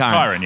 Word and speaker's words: Kyran: Hello, it's Kyron? – It's Kyron, yes, Kyron. – Am I pronouncing that right? – You are, yeah.